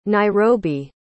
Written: nairobi

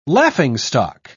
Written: laughingstock

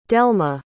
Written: delmi

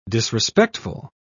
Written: disrespectful

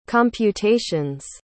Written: computations

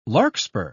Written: larkspur

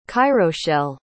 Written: cairoshell

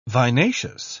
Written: vinaceous

